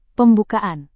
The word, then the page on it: pembukaan: Indonesian